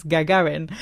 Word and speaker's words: Gagarin: That was public service broadcasting, and they actually gave me the pronunciation, which is Gagarin.